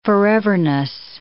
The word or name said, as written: foreverness